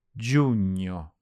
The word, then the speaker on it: giugno: Giugno. Giugno. – There's a GN in the middle of this one, which sounds like the ny in lasagne.